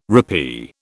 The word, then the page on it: rappee: British English